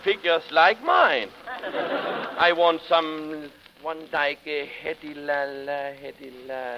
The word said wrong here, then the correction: Hedy Lamarr: Hedy Lamarr? – How do you spell it?